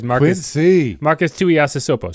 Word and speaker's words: Marcus Tuiasosopo: Marcus Tuiasosopo. So, I mean, like. – Good pronunciation, sir.